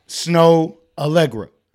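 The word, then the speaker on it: Snoh Aalegra: First, her name is spelled S-N-O-H. – Snow Allegra.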